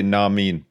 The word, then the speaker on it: nah mean: Actually, you said it wrong, actually. You're supposed to say nah mean.